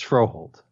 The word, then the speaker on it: Froholdt: Yelda is the first name, and then the last one is F-R-O-H-O-L-D-T. – Froyo. Just kick out the D. It's Froholt. – Yeah. Yelda Froholt and Yadni Kajust.